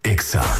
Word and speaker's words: Exa: No se dicen éxitos, se dice Exa.